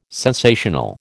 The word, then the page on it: sensational: American English